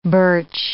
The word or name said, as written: birch